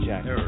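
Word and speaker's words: Herer: Have I pronounced it correctly, Jack? – Hare. He likes Hare. To say it rhymes.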